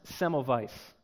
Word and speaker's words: Semmelweis: Not long ago, I read this story about a doctor named Ignaz Semmelweis, which I am told by a German doctor who attends this church that I actually pronounced that correctly.